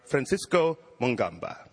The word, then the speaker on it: Francisco Mungamba: Ladies and gentlemen, please welcome corps de ballet member Francisco Mungamba. – Thank you. – Did I say your name right? – Yes.